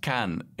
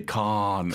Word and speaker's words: Cannes: For instance, like Cannes in France, the town, is pronounced Cannes in French. But Americans will say Khan because they think it's like the wrath of Khan. – Honestly. – Because they think that's the French pronunciation of that word.